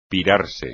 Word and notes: pirarse: espagnol

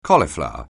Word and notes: cauliflower: anglais

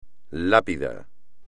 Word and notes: lápida: Spanish

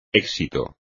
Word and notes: éxito: espagnol

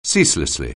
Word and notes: ceaselessly: anglais

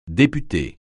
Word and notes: député: francais

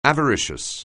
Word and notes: avaricious: anglais

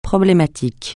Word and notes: problématique: francais

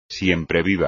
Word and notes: siempreviva: espagnol

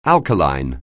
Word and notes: alkaline: English

